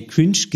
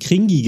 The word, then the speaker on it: cringe: Aber neulich habe ich gehört, wie die Kids gesagt, die haben nicht mehr Cringe gesagt, sondern die haben Kringi gesagt. – Kringi? – Ja, also die haben das nicht englisch ausgesprochen, sondern haben das C so wie ein K ausgesprochen und das E am Ende wie ein E.